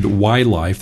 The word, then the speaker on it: WiLife: I'm with this just terrific company named WiLife. That's spelled W-I-L-I-F-E, kind of like wireless life.